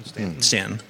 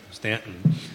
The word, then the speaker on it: Staunton: Did I say that right? Staunton? – Staunton. – Staunton.